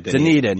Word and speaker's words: Dunedin: Now you've got to go to Dundian. I always say it wrong. Whatever. – Dunedin. Yeah.